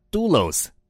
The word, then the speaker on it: doulos: 仆人这个希腊文念作 doulos，意思更精确一点的说是奴隶。